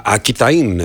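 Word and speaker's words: Aquitaine: Ακιταΐν είναι στα γαλλικά, αλλά γράφεται Ακουιτανία που δεν προφέρεται βέβαια, το καταλαβαίνετε, αλλά εμείς επειδή έχουμε μία τάση να προφέρουμε τα πάντα, φαντάζομαι ότι αυτή είναι η περιοχή του Μπορντό.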